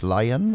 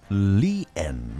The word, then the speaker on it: Lian: Geschrieben tatsächlich L-I-A-N, also müsste man eigentlich sagen Lian, ja, wie es aber im Englischen so ausgedrückt wird, aber hier möchte sie anders genannt werden, ja. Nicht Lian, sondern Lian möchte sie ausgesprochen werden.